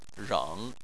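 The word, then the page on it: reng: tone 3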